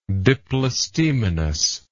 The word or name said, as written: diplostemony